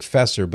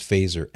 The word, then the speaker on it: Feser: It looks like Fesser, but it's pronounced Fazer, F-E-S-E-R.